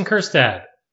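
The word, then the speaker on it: Kjerstad: Heston Kerstad, Silent J, as it were.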